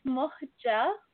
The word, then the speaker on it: Muhga: It's Muhga Eltigani. So both of the G's are pronounced like a J.